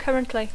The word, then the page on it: currently: English